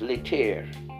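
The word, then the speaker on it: Laetare: L A E T A R E. I, I like to pronounce that as Leter on Leter Sunday, the fourth Sunday of Lent.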